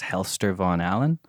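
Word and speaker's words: Hellster Von Allen: It's under my producer name, which is Helster Von Allen. – How do you spell it? You can find it on SoundCloud. H-E-L-L-S-T-E-R.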